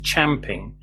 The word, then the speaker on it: champing: I was taught that the expression used champing, where champ rhymes with ramp in British English, and that it was describing a horse grinding its teeth on the bit in its mouth in frustration.